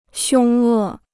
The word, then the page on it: 凶恶: xiōng è